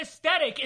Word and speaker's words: aesthetic: the aesthetic is being ruined. – Okay, you pronounced that really weird. – I'm sorry, aesthetic.